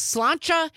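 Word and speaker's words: sláinte: Amy from Madison texting in on the text line saying the correct pronunciation of the Irish cheers to your good health is slancha.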